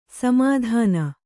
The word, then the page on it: ಸಮಾಧಾನ: samādhāna